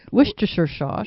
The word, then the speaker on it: Worcestershire: Worcestershire sauce? – Worcestershire. Oh, no. Worcestershire sauce.